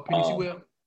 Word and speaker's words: Penei Sewell: I don't want to butcher his first name, but I think it's Penei Sewell. – Oh, Penei Sewell.